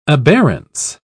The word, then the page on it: aberrance: British English